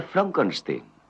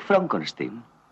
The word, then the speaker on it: Frankenstein: No, se pronuncia Frankenstein. ¿Dice usted también Froderick? No, Frederick. ¿Y por qué no es Froderick Frankenstein? Porque no, es Frederick Frankenstein.